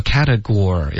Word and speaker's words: category: Okay, 不错 ,category, 要注意一下 gore, gore, okay, 有一个锅的发音结尾加一个儿化音 ,gories.